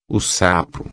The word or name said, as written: sapo